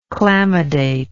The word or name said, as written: chlamydate